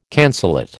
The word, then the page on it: cancellate: American English